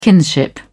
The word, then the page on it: kinship: British English